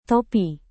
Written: topi

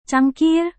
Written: cangkir

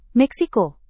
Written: Meksiko

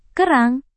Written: kerang

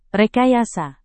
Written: rekayasa

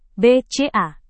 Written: bca

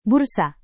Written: bursa

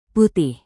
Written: putih

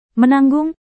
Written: menanggung

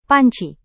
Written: panci

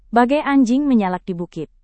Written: bagai anjing menyalak di bukit

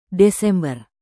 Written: desember

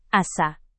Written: asa

